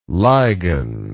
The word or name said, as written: ligan